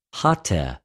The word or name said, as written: ὁτε